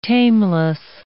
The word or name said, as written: tameless